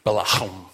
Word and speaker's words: Balaam: Balaam is how you say it.